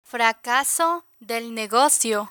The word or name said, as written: fracaso del negocio